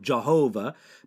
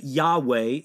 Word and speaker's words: Yahweh: More ancient pronunciations of that would sometimes be the word Jehovah, but Yahweh is a better, or Yahweh is a better pronunciation of that.